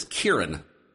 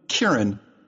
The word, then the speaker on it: Kieran: How do you say his first name? – I believe it's Kieran. – Oh, that's good for me to know. Kieran.